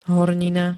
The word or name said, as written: hornina